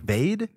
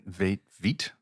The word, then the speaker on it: Veidt: V-E-I-D-T. Is that Vade? – Veit. – I like Veit. Sounds Veit. – Good. – Veit. – Let's go Veit.